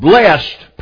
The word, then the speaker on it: blessed: The word blessed, pronounced with one syllable, it is the past tense of the verb bless.